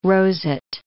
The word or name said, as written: roset